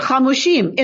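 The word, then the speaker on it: Hamushim (חמשים): The Vav is missing. But we pronounce it Hamushim.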